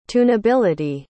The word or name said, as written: tunability